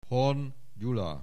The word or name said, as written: Horn Gyula